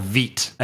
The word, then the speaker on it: Vite: And we'll get into this in a little bit, but I think it's called Vite, I think is actually how that thing is pronounced, believe it or not.